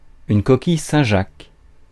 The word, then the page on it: coquille Saint-Jacques: /kɔ.kij sɛ̃ jak/